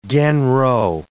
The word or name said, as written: genro